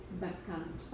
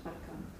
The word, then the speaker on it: Bacchant: So the pronunciation is Bacchant? – Bacchant, yeah.